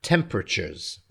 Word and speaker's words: temperatures: And in terms of pronunciation, average, yeah, spelled A-V-E-R-A-G-E, average. Okay, so we sort of run the last two syllables together, average. Good. Temperatures. Okay, so we're not saying temperatures, temperatures. Again, we're pushing the last two syllables together. Temperatures, temperatures. Okay, so in the next 10 years, average temperatures will probably go up slightly, slightly.